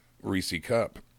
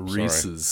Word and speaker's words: Reese's: Reese created the Reese Cup. Reese's cup. – Reese's.